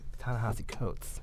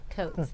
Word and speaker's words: Ta-Nehisi Coates: Ta-Nehisi Coates. – Coates.